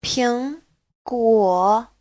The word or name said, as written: ping2guo3